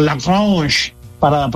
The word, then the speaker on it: Lagrange: Es un lanzador que tiene lo que llaman los americanos un superior stuff, y estamos hablando de Carlos Lagrange o Lagrange, para pronunciarlo en correcto francés.